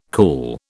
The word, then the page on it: cool: British English